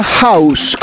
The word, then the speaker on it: Houzz: Esta aplicación se llama House, como casa en inglés, pero se escribe H-O-U-Z-Z.